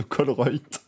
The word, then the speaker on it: Colruyt: le magasin, c'est C-O-L-R-U-Y-T. J'ai essayé, j'ai pas, pas réussi. Et du coup, donc ça, ça, se prononce Colruyt.